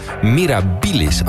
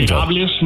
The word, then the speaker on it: mirabilis: Heb ik het goed uitgesproken? – Ja, klopt. Ja, fijn, mirabilis mag wat sneller, maar het was op zich...